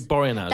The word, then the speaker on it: Boreanaz: How do you say that? – David Boreanaz. – That's it. David Boreanaz.